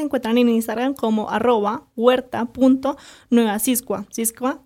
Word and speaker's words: Xisqua: Bueno, nuestras redes sociales nos pueden encontrar en Instagram como Huerta Nueva Siscua. Siscua se escribe Xisqua, ¿sí?